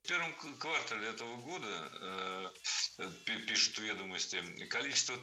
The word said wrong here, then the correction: квартал: Не квартал, а квартал. Причем такое ударение актуально при любом значении слова. И часть улицы, и часть года. Всегда в слове «квартал» ударяем второй слог.